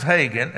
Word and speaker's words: Hagin: With a name as easy to pronounce as Hagin, H-A-G-I-N, I've been called everything.